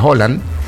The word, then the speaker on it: Haaland: dicen que se pronuncia Haaland, y Kylian Mbappé.